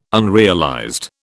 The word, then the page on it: unrealized: British English